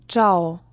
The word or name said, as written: Tschau